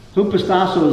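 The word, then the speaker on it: hupostasso (ὑποτάσσω): Hupostasso is a Greek word. It's not hippopotamus.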